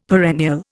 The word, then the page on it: perennial: Indian